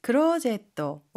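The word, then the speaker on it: クローゼット: 클로젯, 클로젯, 클로젯, 옷장, 붙박이장인데요. 혹시 발음 팁 있을까요? – 하이. 체아는 의자를 뜻하는 영어 체어의 일본어 발음입니다. 그리고 클로젯은 중간에 장음, 소음에도 신경 써주세요.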